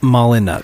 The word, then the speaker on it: Molyneux: Molyneux. – I believe it's pronounced Molyneux. – Yeah. It's French.